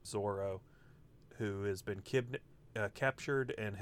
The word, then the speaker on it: Zoro: So, for instance, like, the first crewmate he meets is this swordsman named Zoro, or Zolo, as it's known in the manga. It's pronounced Zoro, but it's the sort of, like, Japanese to English translation thing where the L and the R pronunciation is transposed.